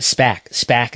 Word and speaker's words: SPAC: From Bloomberg, Berrios and Wilson, SBAC may acquire Bowlero. Bowlero Corp, a operator of bowling centers at SPAC is the proper pronunciation.